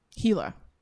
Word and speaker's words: Gila: Gila. That's how you say it. Gila Monster.